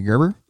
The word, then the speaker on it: Gerber: I mean, that's awesome for Bret Hard G. Gerber.